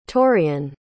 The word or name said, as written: torrian